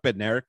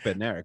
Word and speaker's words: Bednarik: And it's like, I guess this brand of British track guy has never heard of Chuck Bednarik. But like, well, where I come from, we don't pronounce the D's in names. – The confidence that this is a silent D when it's not, it was so crazy to me.